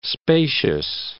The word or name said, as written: spacious